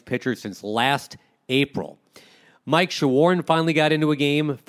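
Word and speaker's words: Shawaryn: But he says it Shawarn, like I'm trying to shorn you about the impending Texas Rangers series, and we'll get to that in short order.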